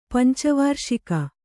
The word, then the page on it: ಪಂಚವಾರ್ಷಿಕ: panca v ārṣika